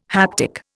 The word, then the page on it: haptic: Indian